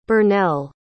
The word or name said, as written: burnell